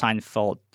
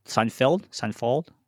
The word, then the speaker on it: Seinfeld: I also wrote down that I did watch Seinfeld's new special. Crazy that he has a new special. – I did not. I've never watched Seinfeld. – Seinfeld.